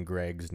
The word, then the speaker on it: Greig: I have looked it up, and the internet claims it's Greg.